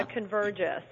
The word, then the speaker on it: Convergys: And I understand you began your career at, help me pronounce this, Convergys? – Yes, yeah. Convergys.